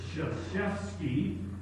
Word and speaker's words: Krzyzewski: It's Krzyzewski, Mike Krzyzewski.